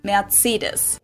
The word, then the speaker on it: Mercedes: So most Americans call this Mercedes. In German, we pronounce it Mercedes.